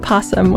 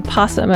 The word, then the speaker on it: opossum: This is going to be a struggle for me because of the word possum, which is spelled opossum, and commonly said possum. And I went down this whole rabbit hole of how is this supposed to be pronounced, and there's a lot of discussion about it, but I'm going to go with possum.